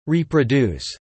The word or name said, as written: reproduce